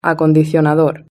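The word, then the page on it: acondicionador: European Spanish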